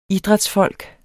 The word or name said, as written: idrætsfolk